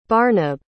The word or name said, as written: barnabe